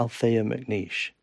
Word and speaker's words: Althea McNeish: Is it Althea McNeish? – Yes.